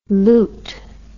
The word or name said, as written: lieut